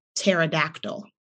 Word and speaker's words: pterodactyl: And we get to the word pterodactyl. If you don't know, the pterodactyl word starts with the letter P. It has a silent P.